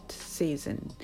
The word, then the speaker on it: season: Autumn is my favorite season、 Autumn is my favorite season。秋は私の大好きな季節です。発音はシーズンそんなに難しくないんですが、カタカナ語でシーズンとなりがちなので S の発音に注意をしてみましょう。 C ではなくて C ですね。